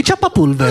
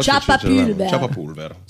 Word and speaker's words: ciappapulver: I ciappapulver. L'ho detto bene, e ciappapulver. – Ho detto bene, la U un po' più con la U, però ciappapulver.